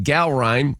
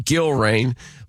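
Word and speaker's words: Gallrein: The president kind of butchered the name of Ed Gowrine as he gave him his endorsement, called him Gilrain.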